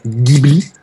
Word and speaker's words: Ghibli: Ghibli, c'est un mot italien, ça se prononce avec un G dur, on dit bien Ghibli, même si certains japonais le prononcent avec un G mou et disent Ghibli.